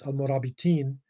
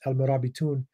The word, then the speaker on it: Al-Murabitun: It's pronounced Al-Murabitin, you know, and when it's majrur, it's in a sentence, but it's Al-Murabitun, you know, when it stands by itself.